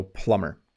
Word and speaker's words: plumber: Notice you don't pronounce the letter B. So plumber.